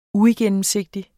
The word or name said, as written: uigennemsigtighed